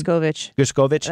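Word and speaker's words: Gershkovich: But then you look at like Evan, how do you say his last name again? – Gerskovich. That's what I'm saying this hour. Next hour would be Gerskovich.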